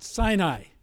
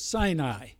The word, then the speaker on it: Sinai: But I believe the proper pronunciation is Sinai.